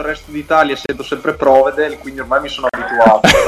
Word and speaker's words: Provedel: Peraltro, Ivan, Provedel o Provedel? Perché insomma, è importante anche... – Vero, piazzare l'accento è fondamentale, quindi sentiamo dal diretto interessato. – Allora, dalle mie parti si dice Provedel.